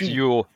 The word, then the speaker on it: Jöl: Pronounce Yule if you're wondering the pronunciation on that.